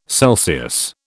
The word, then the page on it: Celsius: British English